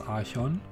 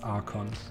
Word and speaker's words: Archon: Ähm, ich glaube, die deutsche Aussprache ist Archon und die englische ist Archon. Wir bleiben bei Archon, wir spielen englisches Magic.